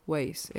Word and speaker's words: Waze: Tak, tak že když se to vysloví, tak je to Waze, jako cesty.